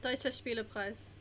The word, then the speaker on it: Deutsche Spielepreis: Yeah, Deutsche Spielepreis. – Thank you. – Oh, the Deutsche Spielepreis.